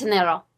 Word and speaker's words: Janeiro: You don't pronounce the J, right? It's super soft. – It's Janeiro.